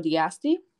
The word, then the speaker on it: d'Asti: It's a Moscato d'Asti. Is that how you say it? D'Asti? – Yeah. Okay. Sounds good to me.